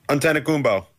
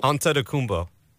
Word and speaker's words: Antetokounmpo: Antetokounmpo. It's not Giannis? That difficult. – Antetokounmpo.